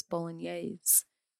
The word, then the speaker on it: Bolognese: See, and I said it wrong the last time because I was drinking too much, but it's Bolognese.